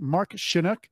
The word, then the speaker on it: Mark Shunock: We're only speaking with Canadian born Las Vegas legendary host, announcer, actor, and philanthropist Mark Shunock.